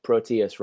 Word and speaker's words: Proteus: I realize I just pronounced Protus wrong. It's Proteus, right? Okay.